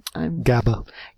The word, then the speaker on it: GABA: GABA. – GABA? – Gamma-aminobutyric acid.